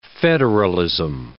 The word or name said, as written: federalism